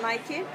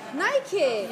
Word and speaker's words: Nike: It's pronounced Nike. – Nike. – Yeah. – That's a good tip. You see, if you learn English with me, Nike.